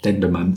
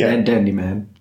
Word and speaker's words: Dendemann: Ja, Dendemann. Ist nicht Dandyman.